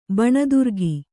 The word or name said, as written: ಬನದುರ್ಗ